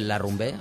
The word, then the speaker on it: La Rumbe: Si tú pones en el Google la rumbe, así, como la rumba, pero con acento en la E, la rumbe, y ya te aparece todo.